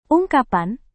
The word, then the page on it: ungkapan: Indonesian